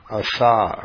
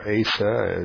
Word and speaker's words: Asa: a king by the name of Asa, or Asa, as English pronunciation often is. By the way, in the Hebrew, there's no long A as A, so every time we say something like A, it's not really right, but when we go for Asa, you know, people look at it and say, oh, it's Asa.